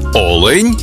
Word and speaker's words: олень: І в однині, і в множині наголос падає на перший склад. Олень та олені.